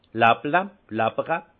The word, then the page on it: làpple: Haut-Rhin; Munster